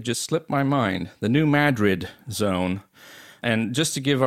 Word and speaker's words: New Madrid: Earlier, I referred to the earthquake zone in the Midwest as the New Madrid zone.